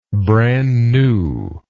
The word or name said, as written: brand new